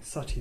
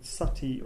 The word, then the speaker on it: Sati: Now, Julia Less has written an interesting article called Sati or Sati, S-A-T-I, long I, or S-U-T-T-E-E, Sati or Sati, in which she presents two different views of this practice.